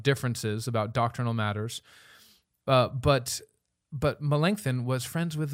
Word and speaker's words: Melanchthon: Melanchthon. – Did I do it? Melanchthon.